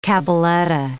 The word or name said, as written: cabaletta